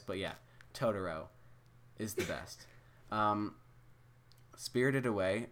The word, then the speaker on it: Totoro: I guess I'm just going to be doing a few Studio Ghibli movies I've seen, like My Neighbor Totoro, which I saw, like, last week, and... – Isn't it Totoro? – Yes, it's Totoro. I'm sorry. – You pronounced it wrong.